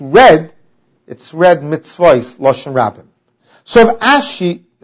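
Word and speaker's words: Mitzvois (מצות): One, the Chibar Ravama Yesh Ein L'Mikra, we go according to the way it's pronounced, and it's pronounced Mitzvois, Lashon Rabbim, that means you have to have at least two pieces of meat.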